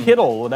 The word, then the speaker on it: Kittle: Kittle, that doesn't... – Skittle. – You know what that rhymes with? – It rhymes with Skittle.